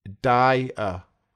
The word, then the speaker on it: Dier: There are two syllables in his surname, and it sounds a bit like a person who dies. Dier. So you can have a builder, a baker, and a dyer.